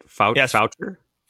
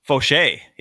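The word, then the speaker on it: Faucher: Calvin Fauché. Is that how you say it? Foucher? – Fauché. Yes, Fauché.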